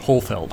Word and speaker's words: Holfeld: Well, it was like Blofeld, the James Bond villain, which they've mercifully brought back. So now I can correct everyone on my pronunciation of my surname. Blofeld, like in James Bond. With an H, Holfeld.